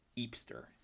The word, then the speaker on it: hipster: And as Benoit says it himself, he embraces this label, but he pronounces it eepster. He says, yes, I am eepster.